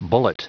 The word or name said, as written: bullet